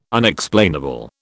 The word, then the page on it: Unexplainable: British English